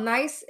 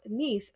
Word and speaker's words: Nice: And I wanted to go to what I was in my mind saying nice, but it's actually pronounced niece.